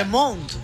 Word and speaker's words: Le Monde: Le Monde.